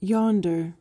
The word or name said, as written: yonder